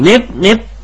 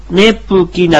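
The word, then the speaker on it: ネㇷ゚キ: ネプキ、ネプキ。ネプキな、カナで書くとネプキなんですが、プが、プをカナではちっちゃく書きます。これ、ネプキという発音になります。口を閉じるような発音ですね。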